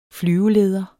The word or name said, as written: flyveleder